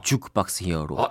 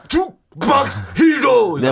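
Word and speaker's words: Juke Box Hero: 뭐 주크박스 히어로. 아, 주 박스 힐러.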